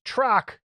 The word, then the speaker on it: Trock: It's pronounced Trock, a rock with a T in front of it.